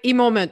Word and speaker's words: eMoment: Oui, eMoment. Ben, il y en a qui aiment mieux le dire à la française, là, un moment, mais c'est vraiment dans Emotional Moment. oui, eMoment.